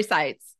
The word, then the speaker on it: Saitz: Yeah, Saitz. Lori Saitz.